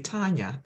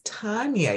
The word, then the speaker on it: Tania: Sometimes instead of saying Tania, I say Tanya.